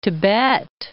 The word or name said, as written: Tibet